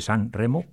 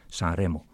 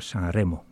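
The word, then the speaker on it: Sanremo: En castellano se dice San Remo, pero en italiano es San Remo. Pues la R no suena así, hay que sonar San Remo, no pueden hacer la R.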